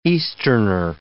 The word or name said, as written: Easterner